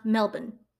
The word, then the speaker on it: Melbourne: Thompson told police that after the war ended, she had moved to Melbourne, but in Australia it's pronounced Melbourne.